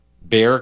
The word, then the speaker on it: Bexar: I'm sorry, how do we pronounce this? – Bear. – Bear County, like in grizzly bear. – It should be Bayar.